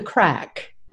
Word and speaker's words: craic: I think called the craic, something, the craic? – It's actually called the crack.